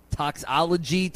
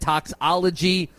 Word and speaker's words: toxicology: Strebel said toxicology. You said toxology.